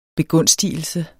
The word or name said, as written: begunstigelse